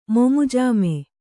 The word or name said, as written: ಮೊಮುಜಾಮೆ